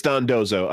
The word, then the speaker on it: Dondozo: Have we ranked Dondonzo yet? – But no. – Or am I is it Dondozo? Oh, it's Dondozo. I've been saying it incorrectly.